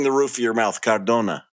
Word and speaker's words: Cardona: Miguel Cardona. – Cardona. Okay. All right. – You don't have to roll the R because it's only one R.